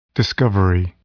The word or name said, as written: discovery